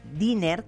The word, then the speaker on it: Diener: Bueno, a ver, apunten en su agenda, en la O de oftalmólogo, Sebastián Diner, que se escribe Diener,